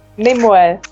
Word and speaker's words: Nimue: Everything feels rushed, and I mean, next week we'll see Nimue. How do we pronounce her name again? – Nimue.